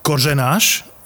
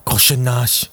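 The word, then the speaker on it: Kořenář: Počúvaj, ty ho vyslovuješ jak tacha. Košenáš. Kozenáš. – Však on, on to Tak po, po polsky to dal.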